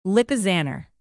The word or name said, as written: lipizzaner